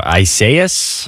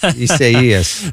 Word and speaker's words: Isaias: See, I was saying it in all sorts of different ways, too. Isaias? – No. Isaias.